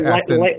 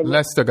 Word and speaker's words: Leicester: Leicester? Leicester got 1.